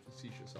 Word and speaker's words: facetious: I'm like I pronounce narrating it my feeling. Facetious. – Thank you. Facetious.